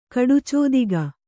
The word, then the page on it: ಕಡುಚೋದಿಗ: Kannada